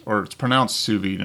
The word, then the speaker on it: sous vide: Now, some of you may have heard about it, but basically sous vide cooking, and it's pronounced sous vide, and it's spelled S-O-U-S-V-I-D-E.